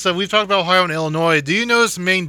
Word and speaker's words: Illinois: You said Illinois, and the first one is there's a lot more French words, and we do tend to mostly pronounce them correctly. It's Illinois.